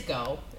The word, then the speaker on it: Geaux: G-E-A-U-X. – It's go. It's just go.